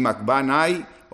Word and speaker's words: Macbanai: Jeremías el décimo y Macbanai, el undécimo, no tiene acento en la I, así que es Nai.